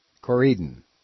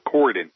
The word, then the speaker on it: Corydon: Is that how you pronounce it? – Yeah. – Corydon. – No, Corydon.